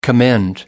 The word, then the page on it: commend: American English